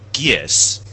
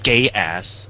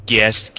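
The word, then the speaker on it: Geass: Best actor in a leading role is Jun Fukuyama as in Lelouch, called It's Gies, not Gay Ass. – Is that the official pronunciation? – Gies.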